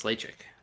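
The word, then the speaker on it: Ledaig: It's spelled E-L-E-D-A-I-G, and it's essentially pronounced Lachig.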